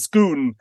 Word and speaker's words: Scone: was first installed at Scone, or as it's pronounced in Scotland, Scone, yeah.